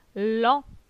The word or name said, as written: Lent